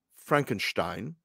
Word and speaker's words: Frankenstein: Well, watching let's start with Peter Cushing as Baron Victor, as he pronounces it. Frankenstein. He says it correct. He says it the Gene Wilder way.